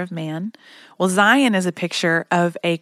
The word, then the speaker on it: Zayin: Our letter for today is the Hebrew letter Zayin, which is spelled Z-A-Y-I-N, but it's pronounced like Zion.